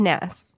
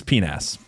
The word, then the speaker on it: PNAS: I feel like the P is silent and it's just NAS. – No, I think it's PNAS. Yeah. I think that's how they want it to be pronounced. Look, it's not my fault if you came up with a silly acronym. I'm just going to read it the way I see it.